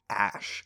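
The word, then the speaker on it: Æ: It's pronounced like Ash. – Yeah, it's Ash. The AE thing where they're like combined is Ash.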